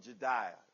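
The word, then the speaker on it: Jedaiah: This word is actually pronounced like Yedea or whatever, but it's, we're going to call it Jediah.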